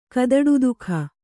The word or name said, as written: ಕದಡುದುಃಖ